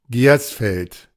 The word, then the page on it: Gersfeld: ˈgɛrsfɛlt; ドイツ語